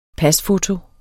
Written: pasfoto

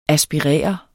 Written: ASPIRER

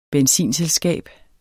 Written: benzinselskab

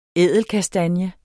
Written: ædelkastanje

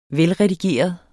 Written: velredigeret

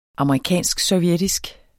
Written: amerikansk-sovjetisk

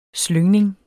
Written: slyngning

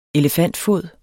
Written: elefantfod

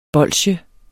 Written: bolsje

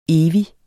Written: evig

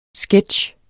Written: sketch

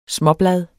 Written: småbladet

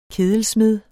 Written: kedelsmed

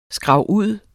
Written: skrabud

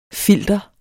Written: FILTERETS

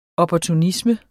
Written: opportunisme

